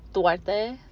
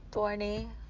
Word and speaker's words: Duarte: Hi guys, welcome to another podcast of Drunk Words, Sober Thoughts by me, Marlene Duarte, Duarte, whatever you want me to call me, but it's spelled D-U-A-R-T-E.